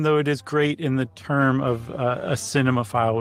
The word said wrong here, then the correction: cinephile: would use it. How about that? A cinephile. – Yes. – I always add an extra syllable.